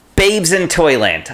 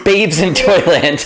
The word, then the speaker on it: Babes in Toyland: Babs in Toyland. – Babes in Toyland, I think is how it's pronounced. I think it is pronounced Babes in Toyland.